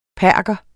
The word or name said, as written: perker